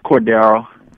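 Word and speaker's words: Cordarrelle: Oh, it's Corderell. – Corderell. See? I told you, Black Tie. You wanted to argue with me about that. No, I say Corderell.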